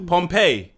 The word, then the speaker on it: Pompeii: P-O-M-P-E-I-I. – Oh, is it? – Yeah. So how would you pronounce that? Pompey. – Yeah, that's how you pronounce it.